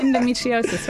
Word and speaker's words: endometriosis: And your pronunciation is excellent, by the way. – Is it? – Yes, endometriosis. Well done.